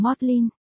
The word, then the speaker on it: Magdalene: Tôi biết trường cao đẳng Magdalene được phát âm là cao đẳng Modlin.